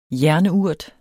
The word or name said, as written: jernurt